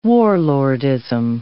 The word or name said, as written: warlordism